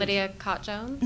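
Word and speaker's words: Lydia Kott-Jones: Lydia Cott Jones.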